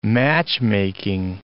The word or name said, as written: matchmaking